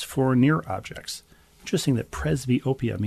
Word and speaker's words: presbyopia: This is a treatment for presbyopia.